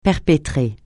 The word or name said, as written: perpétrer